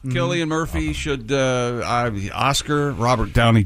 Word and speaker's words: Cillian: Killian Murphy, and I just learned over the weekend that is the way he pronounces his name. – Okay. – I always thought it was Cillian.